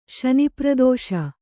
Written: ಶನಿಪ್ರದೋಷ

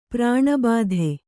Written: ಪ್ರಾಣಬಾಧೆ

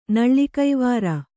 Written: ನಳ್ಳಿಕೈವಾರ

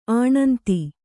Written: ಆಣಂತಿ

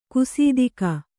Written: ಗಸಿತೆಗೆ

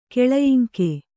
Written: ಕೆಳಯಿಂಕೆ

